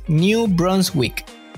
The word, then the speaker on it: New Brunswick: New Brunswick.